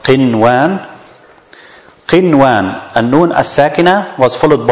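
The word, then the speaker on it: qinwan (قنوان): And the waw is one of the idgham letter. So we should pronounce it qiwan. Qiwan. But is that right? Is that correct? No. Because the idgham cannot be applied in one word.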